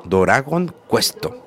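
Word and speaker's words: Doragon Quest (ドラゴンクエスト): Entonces, ¿cómo se dice? Dorago, Doragon Quest. Eh, no hay, no está la, letra D de Dragon, Dragon, no hay, la D no es tan solo.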